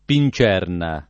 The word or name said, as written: pincerna